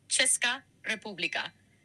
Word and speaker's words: Česká republika: Y se pronuncia aproximadamente Česká república. Detalles. Česká. Če suena como ch de chico. Česká, Česká. República, república, con el acento principal en la primera sílaba, aunque para un hispanohablante suena natural decir república.